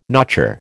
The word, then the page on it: notcher: American English